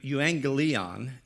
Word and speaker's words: euangelion: It's pronounced in Greek, euangelion, and it means a good message or good news.